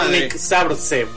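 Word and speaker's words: Górnik Zabrze: Gornik Zabrze, porque vos me vas a decir que se pronuncia de otra manera. – No, no, está muy bien.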